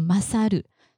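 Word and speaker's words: 勝る: 用法不是很多，所以典型的几个大家记起来，一个是呃胜利的胜，然后念作 masaru，什么什么你 masaru，赢过谁，赢过什么事物。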